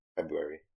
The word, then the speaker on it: February: February. The R is silent. – February. Then why is it there? – Because it's silent. Silent R.